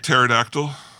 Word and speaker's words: pterodactyl: You know, that's one of my pet peeves, is people that put like a silent E, like the old tap house with O-L-D-E. – Like pterodactyl? – Yeah, exactly. – With the silent P? – Yeah.